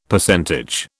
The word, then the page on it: Percentage: British English